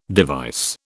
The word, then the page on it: device: British English